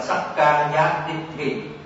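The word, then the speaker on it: sakkāyadiṭṭhi: Chữ này đọc là sắc ca giá đích thị, là cho rằng dịch là thân kiến.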